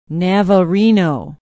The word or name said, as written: navarino